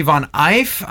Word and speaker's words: von Eif: A wonderful sister in the Lord from New York, Mary von Eif. I'm finally saying that correctly because I guess it's German.